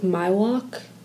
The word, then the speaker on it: Miwok: I think it's pronounced like Miwok. – Okay. – That's how it's said.